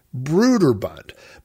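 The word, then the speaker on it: Brøderbund: And I looked up a YouTube interview with one of the founders of the company, and he pronounced it Broderbund, but he doesn't say it long like I said Broder. He said Broder, like Broderbund, almost with the emphasis being on bund, like Broderbund, Broderbund.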